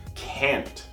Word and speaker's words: can't: Esse can't, quando você for escutar um nativo, especialmente dos Estados Unidos, um americano, um norte-americano, você possivelmente não vai escutar a pronúncia desse T de uma maneira tão clara. Eles começam a fazer o som de t, t, mas param aqui no céu da boca.